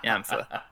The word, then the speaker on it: Ymfah: That's because I didn't tell you how to spell it. Y-M-F-A-H. Yamfa.